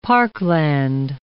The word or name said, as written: parkland